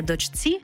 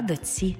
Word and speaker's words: дочці: Пишемо «чці», а вимовляємо «ці». Наприклад, не «дочці», а «доці».